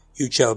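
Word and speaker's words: Uco: Ever say his name clearly, but at the end, they I believe in the language it's Ucho, because the martial art is spelled Pensac, and it's Penchak, so I'm guessing the C makes a ch sound in Indonesian.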